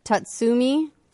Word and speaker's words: Tatsumi: Tatsumi.